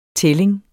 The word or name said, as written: tælling